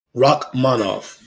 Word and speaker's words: Rakhmonov: But the guy that we definitely should give some mention to, Shavkat Rakhmonov. Rakhmonov. – You said it correctly.